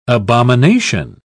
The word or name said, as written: abomination